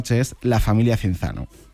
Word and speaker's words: Cinzano: Cinzano en 1940. Y este apellido, pronunciado italiano, quizá no nos suene tanto, pero si decimos Cinzano, nos va a sonar a todos muchísimo más.